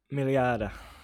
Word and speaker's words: Mealhada: Mealhada. – Mealhada, there you go.